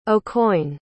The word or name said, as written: aucoin